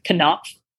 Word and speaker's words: Knopf: Well, as we mentioned before, my first middle grade novel is coming out with Knopf. You pronounce all the letters in that.